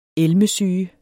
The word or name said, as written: elmesyge